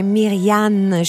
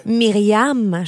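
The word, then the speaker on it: Myrianne: Oui, moi, mon nom, c'est bien Myriane Chantal et non pas Myriam Chantal. – Ce qui serait une vulgaire faute, là. – Oui, oui, moi, c'est vraiment M-Y-R-I-A-N-N-E. C'est vraiment comme tu l'as prononcé, comme tu l'as fait.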